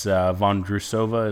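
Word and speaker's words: Vondroušová: But Marquetta, I believe that's Von Drusova is how you say that.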